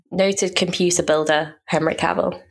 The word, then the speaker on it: Cavill: Cavill? Cavill? – Yeah. – Cavill? – Cavill.